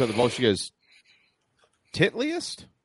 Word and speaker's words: Titleist: Listen, my German teacher was like, "It's titliest, titliest, titliest." yeah, that's how you say it. Look at how it's spelled.